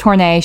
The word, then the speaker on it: Horney: Karen Horney, which is how it's spelled. – Is it? – I Googled it. It's pronounced Horney. She is from Germany.